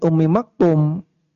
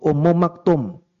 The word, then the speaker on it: Ummu Maktum: Lah kok namanya jadi Abdullah bin Ummi Maktum? Kenapa demikian? Karena beliau dinisbatkan kepada ibunya, yaitu Ummu Maktum. Ya, jadi kalau marfu dibacanya Ummu Maktum, Ummi Maktum karena majrur.